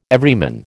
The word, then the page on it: Everyman: American English